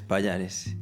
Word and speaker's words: Pallares: creo que pronunciaré bien el segundo apellido, Payares. Sí, exactamente, es Payares.